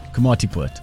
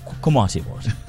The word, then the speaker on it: Komatipoort: This went down on Saturday, the 2nd of May, when human remains were discovered inside a crocodile near Komatipoort. Forgive my pronunciation. – Komatipoort. – Komatipoort. I'm going to say that very soft. Komatipoort.